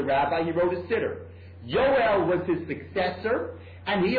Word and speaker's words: Joel: And therefore, if you look on the bottom of the page, on 278, there's an amazing debate between Abraham Geiger and Manuel, how do you pronounce that name in German? Bella Yoel, they say in German? On the bottom, page 278, J-O-E-L, Yoel, okay?